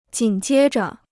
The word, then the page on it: 紧接着: jǐn jiē zhe